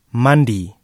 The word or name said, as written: mandi